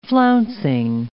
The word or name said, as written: flouncing